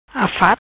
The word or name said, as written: Afat